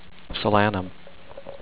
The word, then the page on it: Solanum: so-LAN-num